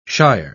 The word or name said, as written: shire